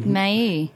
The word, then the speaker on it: Maille: You know, I never know how to pronounce this. M-A-I-L-L-E. – May.